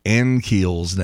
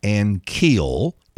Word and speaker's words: Ankiel: Then again, Dan McLaughlin has been sitting up in the broadcast booth at Busch Stadium mispronouncing Rick Ankiel's name for 20 years. It's not Ankiel. It's Ankiel. I've spoken to Dan about it.